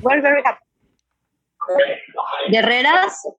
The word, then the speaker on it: Guerreras: But you don't pronounce the U, so you say Guerreras. – Guerreras.